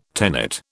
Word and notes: tenet: British English

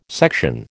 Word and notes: section: American English

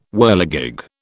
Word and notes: whirligig: British English

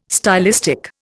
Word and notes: stylistic: Indian

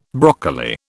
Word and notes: broccoli: British English

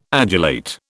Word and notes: adulate: British English

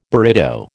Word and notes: burrito: American English

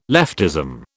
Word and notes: leftism: British English

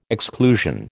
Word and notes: exclusion: American English

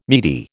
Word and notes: meaty: American English

